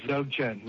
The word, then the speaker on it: Dzogchen: So much gratitude to Lama Surya Das, founder of the Dzogchen Center. Is that how I pronounce it? – Yes, Dzogchen.